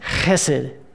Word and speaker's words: chesed: It's chesed. Chesed. What a wonderful word, right? Chesed.